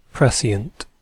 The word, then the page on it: prescient: British English